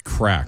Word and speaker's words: craic: They call it Shades of Ireland, where you're going to see castles, cliffs, pubs, music, and crack. Is that C-R-A-I-C? – Is that how I'm pronouncing it? – I don't think it's fun. Pronounced crack.